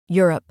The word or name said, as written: Europe